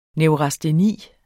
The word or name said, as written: neurasteni